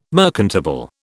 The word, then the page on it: merchantable: British English